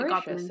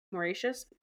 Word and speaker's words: Mauritius: Uh, Mauritius, how do you say that? I'm so bad at this. – Uh, Mauritius, got them seven, Mauritius. Anyways, they got those in 1780.